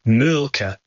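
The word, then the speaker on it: Mölkky: I said Molky. Am I pronouncing it right? – We use Molky. Most of the people we play against use Molky, but the Finns have a very unusual pronunciation, which, please forgive me, I'll try. It's Mulka. So I think that's, something a bit more like that. But to us, it's Molky.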